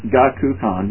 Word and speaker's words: Shogakukan: Now, over a month since the complaint was set, the SEC has not responded to Shogakukan, you said it right the first time.